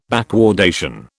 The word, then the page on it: backwardation: British English